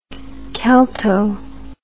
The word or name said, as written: celto